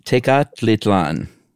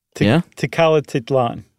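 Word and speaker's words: Tecalitlán: Tecalitlan. – Oh, okay. Is that right? – No. I added a T just to make it easier. I think you said it right the first time.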